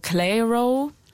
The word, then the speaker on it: Clairo: Es gibt Leute, die sagen so, C-L-A-I-R-O, Clairo. Clairo wäre Loki auch schön.